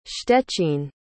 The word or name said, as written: szczecin